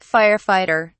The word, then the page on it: fire fighter: British English